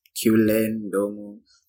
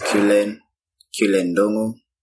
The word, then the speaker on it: Cullen Ndung'u: Okay, my name is Cullen Ndung'u. – What? I've been saying this the wrong way. – Nah, it's not wrong. Some people pronounce it the way you do, but mine is pronounced Cullen Ndung'u.